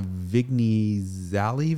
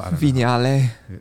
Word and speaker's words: Vignale: You are working on a Vignale video. – Vignale. – But how can it be pronounced Vignale when it's not French? GN isn't a French thing. – No, like lasagna.